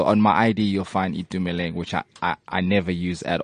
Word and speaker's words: Thumeleng: I know Thumi, but Thumeleng, is that correct? Am I saying it right? – Yeah, Thumeleng is the obviously the long version, but you know the short version.